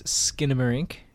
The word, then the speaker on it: Skinamarink: You're back on the Tuesday Review talking all things Skinner Inc.